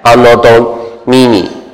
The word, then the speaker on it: Anno Domini: 这个字怎么念？ANNODONEMINI，哎，你会念吗？拉丁文 Anno Domini，这就是公元啊，用拉丁文写的。